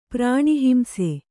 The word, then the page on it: ಪ್ರಾಣಿಹಿಂಸೆ: Kannada